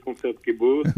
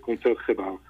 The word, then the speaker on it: Concertgebouw: En français, on a tendance à dire Concertgebouw. – Oui. – Si tu en as un peu mieux à nos oreilles, mais effectivement, en néerlandais, ça se prononce Concertgebouw.